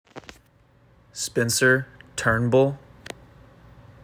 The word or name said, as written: Spencer Turnbull